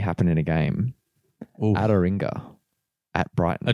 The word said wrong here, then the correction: Adingra: No. How do you say his name? – Adingra? – Adingra.